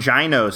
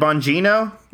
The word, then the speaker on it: Bongino: Is it Bongino? – It's not. It's not Bongino. It's either a hard G or a Eno.